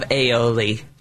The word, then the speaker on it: aioli: And Chef, how do you say aioli in France? I have asked you this. – Aioli. – So you pronounce the I at the end.